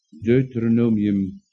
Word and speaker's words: Deuteronomium: Deuteronomium, zeg ik het goed, 6, ja.